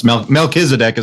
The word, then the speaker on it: Melchizedek: Melchizedek. Sound familiar, Pastor? – Yes, Melchizedek is the way I'd say it.